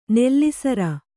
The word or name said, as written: ನೆಲಸರಿ